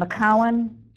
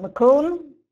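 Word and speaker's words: Macoun: We have many well-known varieties, Empire, Cortland, Jonna Gold, and McCowan. Don't pronounce it McCoon. You can tell you're not from upstate New York if you say McCoon instead of McCowan.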